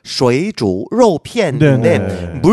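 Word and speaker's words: 쉐이주 러피엔인데 (水煮肉片): 그 쉐이저 러피엔. – 네. 쉐이주로 그게 정확한 발음은 쉐이주 러피엔인데 물 숫자 그다음에 이게 끓이다라고 할 때 두라는 그런 한자가 있어요.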